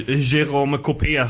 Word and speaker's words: Jérôme Copé: Eh, eh det var ju Jérôme Copé som han heter tydligen med på Twitter. Bra uttalat. Tack, tack. Jag är ju en, vad kan man säga, frankofob.